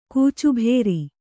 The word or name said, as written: ಕೂಚುಭೇರಿ